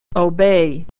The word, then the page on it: obey: e-ey